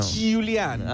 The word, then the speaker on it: Giuliano: Pero lo tenés que pronunciar así, Giuliano. – Giuliano.